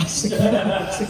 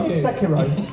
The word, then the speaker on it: Sekiro: Sekiro. – Sekiro.